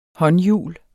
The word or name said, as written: håndhjul